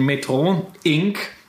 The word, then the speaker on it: Metro Inc: Der ein oder andere Dividendenfan wird die Aktie vielleicht kennen, wie vielleicht auch die Metro generell, denn Metro Inc französisch ausgesprochen aus Montreal.